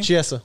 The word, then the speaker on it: Chiesa: How do you say his name? – Chiesa.